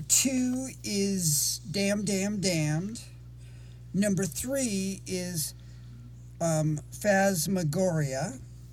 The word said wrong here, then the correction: Phantasmagoria: Uh-huh, Phantasmagoria, yeah. – Yes, thank you. I can never say that right.